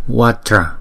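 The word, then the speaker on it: Vajra: Chữ này chúng ta đọc là Watra. Chúng ta đọc chỉ có hai âm mà thôi.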